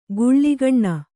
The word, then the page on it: ಗುಳ್ಳಿಗಣ್ಣ: guḷḷigaṇṇa